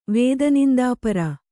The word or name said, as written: ವೇದನಿಂದಾಪರ